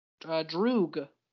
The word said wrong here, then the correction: Druig: How is it pronounced? – Druig. Not Droog. – Whatever. – You see the I in there, right? – Yeah, whatever.